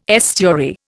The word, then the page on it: estuary: Indian